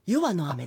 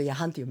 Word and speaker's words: 夜半: 詩縁とあと夜半の雨という、あ、夜は、あ、夜はの雨です。 – 夜はの雨なんですね、はい、失礼いたしました。 – ニュースで読むと夜半と読みますよね。